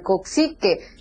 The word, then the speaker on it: Coxsackie: Hay mucha información, sobre todo esta alerta por este virus, ¿cómo se pronuncia correctamente este virus? – Buenos días. Claro que sí, Lucero, muy buenos días para ti, para toda la audiencia. Es el virus del coxique.